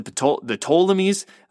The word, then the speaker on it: Ptolemies: And then the kingdom of the south would have been known as the Ptolemies. The P is silent there at the beginning.